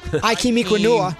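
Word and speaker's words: Ikem Ekwonu: Ikem Ekwonua.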